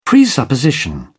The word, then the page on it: presupposition: British English